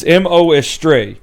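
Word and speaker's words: Mo: Astray: I think this is a colon, Estray. It's Moastray. And, like, the character's name is Mo.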